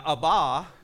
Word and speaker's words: Abba: Now, Abba is actually how it's pronounced.